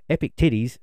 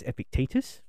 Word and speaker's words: Epictetus: And it took me ages to be able to make sure I said it properly so I don't come on here and say, Epic Titties said this. Okay? It's Epictetus. It is ye old Latin and Greek. So it's pronounced differently.